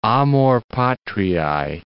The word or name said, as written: amor patriae